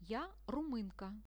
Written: ja rumynka

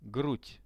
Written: grud

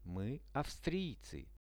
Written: my avstriecy

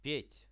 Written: pet

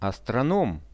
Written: astronom

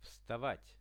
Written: vstavat

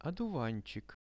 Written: oduvanchik

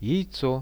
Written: jaico

